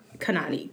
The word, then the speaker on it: Khanani: Next is Thorn by, I'm going to butcher this name, Intisar Khanani. I think we got the last name right. Khanani. K-H-A-N-A-N-I.